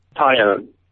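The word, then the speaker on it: Taillon: How do you say it? – Tyone. – Like I'm going. Tyone. I'm going to tie on this rope to this stick. – No, you're going to own this tie.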